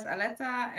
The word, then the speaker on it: Aletta: My name is Caroline and my today's interview guest is Aletta. I hope I pronounced it right. – Yes.